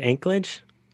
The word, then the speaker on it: Anchorage: And living in, how do you pronounce it, Anchorage? – Anchorage, like an anchor.